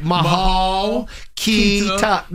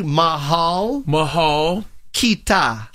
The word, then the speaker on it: Mahal Kita: Mahal Kita. – No. Crew neck. – Hey, man. Listen. Listen. Okay. Can you listen? – Okay. – All right. You ready? Mahal Kita.